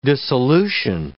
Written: dissolution

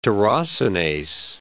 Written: tyrosinase

